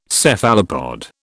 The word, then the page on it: Cephalopod: British English